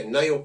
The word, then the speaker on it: Niall: Is that how you say his name? – I think it's, Niall Colgan, and it's N-I-A-L-L Colgan, and it's these vlogs that he does, and I just think he's a guy that's surreal.